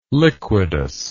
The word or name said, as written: liquidus